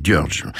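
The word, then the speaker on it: György: Ça s'écrit Georgie en français, mais ça dit George. D'après mes amis hongrois.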